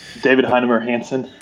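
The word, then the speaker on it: David Heinemeier Hansson: David Heinemar Hansen.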